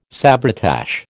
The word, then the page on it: sabretache: American English